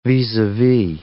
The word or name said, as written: vis-a-vis